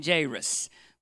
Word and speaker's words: Jairus: When two vowels go walking, the first one does the talking. And so you've got A and I together. That means it's a long A. So we're going to call him Jairus, which is, I think, the correct pronunciation.